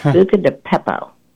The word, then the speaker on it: Buca di Beppo: Buca di Beppo.